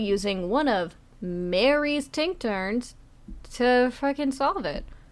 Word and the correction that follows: tincture: A tincture or what? I don't know how you pronounce it. Tincture.